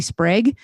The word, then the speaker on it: Sprague: So if everyone could go um, go on Spotify or Apple Music or whatever and download and listen to Sydney Sprague, it rhymes with egg, but it's spelled like Prague.